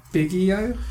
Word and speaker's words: Biggio: Andrew Biggio is how I believe it is pronounced. – Biggio. – B-I-G-G-I-O.